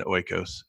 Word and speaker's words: oikos: Restoring our, and I might mispronounce the word, oikos, O-I-K-O-S, like Sam, oikos.